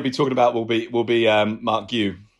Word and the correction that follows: Guiu: Marc Guiu.